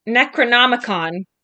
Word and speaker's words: Necronomicon: The book, the Necronomicon. Did I say that right? – You did.